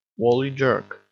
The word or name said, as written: wally jerk